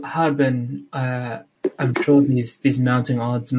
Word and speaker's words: Harbin: Did you say your name is Hardon? – It's Harbin. Harbin.